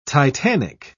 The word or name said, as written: titanic